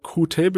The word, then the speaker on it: Qt: Genau, also das GUI Toolkit wird Qt benutzt, was offiziell auch Qt ausgesprochen ja. wird.